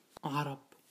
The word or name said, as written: Arabs